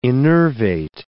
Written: innervate

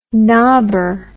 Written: knobber

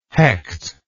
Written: Hecht

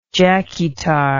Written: jackietar

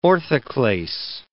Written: orthoclase

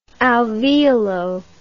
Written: alveolo

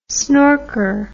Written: snorker

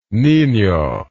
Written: nino

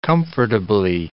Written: comfortably

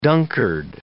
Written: Dunkard

